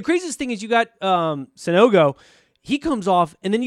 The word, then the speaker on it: Sanogo: It is Sanogo.